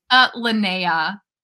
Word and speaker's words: Linnea: Linnea.